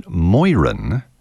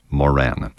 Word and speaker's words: Moeran: It looks like Ernest J. Moran, M-O-E-R-A-N. His father was Irish, his mother English. The Irish apparently pronounce it Moiran, but I've also heard Ernest J., he also went by E.J., E.J. Marin. Be it as it may, I'll say Ernest John Moran.